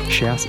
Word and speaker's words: Shouse: Next on my list, I've got the Frankie Wah remix of Love Tonight by Shouse. I think I'm saying that right. Shouse, it's house with an S in front of it. Shouse.